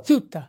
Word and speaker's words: Suetta: His name is S-U-E-T-T-A. Suta? Is that how you pronounce it? S-U-E-T-T-A. – Suta. – See, he had it better than I did.